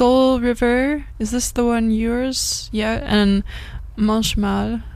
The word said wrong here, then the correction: manchmal: I can't not say it like French. I'm sorry. – I, I, don't even know if it's in French actually. Ah, okay. it's in German. It's German. Ah, okay. Manchmal. Manchmal.